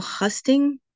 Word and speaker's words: husting: What is political husting? I've never heard of this term. – So, did I say it right? – Yeah. So you've said it right.